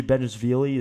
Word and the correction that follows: Bezhanishvili: is one of those guys uh, who, Bezhanishvili, that, that's how you pronounce it, Bezhanishvili.